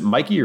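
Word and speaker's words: Michie: And it is a, how do you pronounce the stadium? Is it Mikey or it's spelled Mitchie, but I think it's pronounced Mikey.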